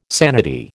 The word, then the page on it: sanity: American English